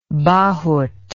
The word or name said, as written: bahut